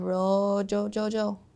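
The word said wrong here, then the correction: Mojo Jojo: Sorry, I said it wrong. It's Mo-Jo-Jo-Jo.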